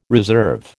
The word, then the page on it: reserve: American English